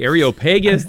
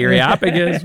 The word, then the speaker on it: Areopagus: The Areopagus, the Areopagus.